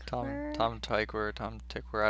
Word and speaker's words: Tom Tykwer: Tom Tykwer, I don't know.